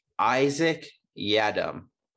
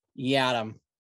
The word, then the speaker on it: Yiadom: Isaac Yiadom. – Yiadom.